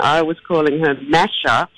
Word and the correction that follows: Masha: Mm-hmm. – But then Nicole Kidman, who has already bought the rights and who will hopefully play this role, she corrected my pronunciation with a beautiful Russian accent and said, no, it would be Masha.